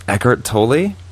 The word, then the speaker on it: Eckhart Tolle: So my pick of the week is The Power of Now by Eckhart Tolle. – Yes. – Is that how you say it? – Yes.